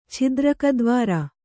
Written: ಛಿದ್ರಕದ್ವಾರ

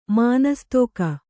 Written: ಮಾನಸ್ತೋಕ

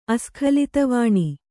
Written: ಅಸ್ಖಲಿತವಾಣಿ

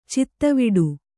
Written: ಚಿತ್ತವಿಡು

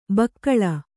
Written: ಭೂಗೋಳ

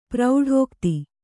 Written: ಪ್ರೌಢೋಕ್ತಿ

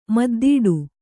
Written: ಮುದ್ದಾಡು

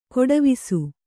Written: ಗೆಡೆವಸು

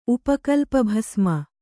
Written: ಉಪಕಲ್ಪಭಸ್ಮ

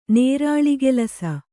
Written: ನೇರಾಳಿಗೆಲಸ

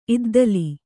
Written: ಇದ್ದಿಲ್

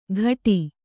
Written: ಘಟಿ

